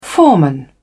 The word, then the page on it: foreman: British English